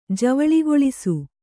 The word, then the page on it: ಜವುಳುಗೊಳಿಸು: javuḷugoḷisu